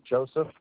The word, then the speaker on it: Joseph: Joseph. – Spelled J-O-E-S or J-O-S-E-P-H? – J-O-S-E-P-H.